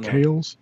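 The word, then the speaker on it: Kahles: Did I say that right? Is it Kales? K-H-A-L-E-S. Yes. Yeah.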